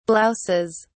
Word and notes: blouses: British English

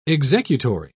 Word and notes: executory: British English

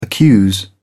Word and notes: accuse: British English

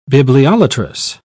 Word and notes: bibliolatrous: British English

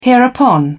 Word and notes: hereupon: British English

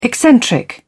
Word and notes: eccentric: British English

